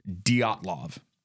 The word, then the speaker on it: Dyatlov: Sometimes the V is pronounced like an F, Dyatlov, but I'm pretty sure it's safe just to stick with Dyatlov.